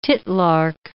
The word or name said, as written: titlark